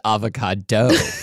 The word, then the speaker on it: avocado: oh, we're, well, we're going to talk a lot about the word avocado also, but apparently I've just learned from you that it's pronounced avocado. it's like Chicago.